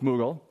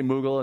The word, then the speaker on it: Mughal: But it turned out that that's the British pronunciation of m- um, and the Americans say Mughal, and the, the Brits say Muggle.